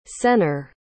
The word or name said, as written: sennar